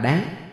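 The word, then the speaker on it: đạn: Đúng ra khi dùng như đơn vị đo lường phải đọc là đáng, nhưng theo thói quen đều đọc là thạch.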